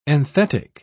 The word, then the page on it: enthetic: British English